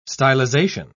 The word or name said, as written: stylization